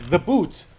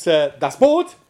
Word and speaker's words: Boot: which the Americans always pronounce in the English as the boot, which means, what does that mean? It means, well, it means, you know, a certain type of shoe, but it's called Das Boot or the boat, not the boot.